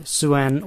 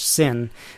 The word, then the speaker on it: Sin: Nabonidus was this Babylonian king, and he devoted himself to the worship of the moon god Suen, or as it's more frequently pronounced in English, Sin, has nothing to do with sin, as in I'm a badass god, the god of sin.